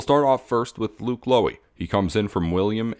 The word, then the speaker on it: Loewe: Lowy was a 3-year starter at William & Mary, and it is Luke Lowy. It's spelled L-O-E-W-E. It's two syllables, Lowy, Lowy.